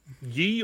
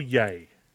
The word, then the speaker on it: Ye: Is it pronounced ye or yay? – Ye. – Yee, it is Yee.